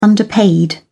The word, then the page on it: underpaid: American English